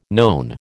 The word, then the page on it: known: American English